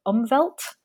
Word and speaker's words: Umwelt: butcher the pronunciation of umwelt.